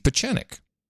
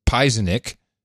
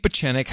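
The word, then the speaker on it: Pieczenik: And I remember bringing in a clip, and I even pronounced it, his name is Pizenik, because I had no idea who he was. Steve Pachenik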